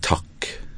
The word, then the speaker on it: takk: Jeg terger av og til både de som bor her med Noe, og kanskje nordmenn spesielt, at på norsk så uttales ordet takk med stum T.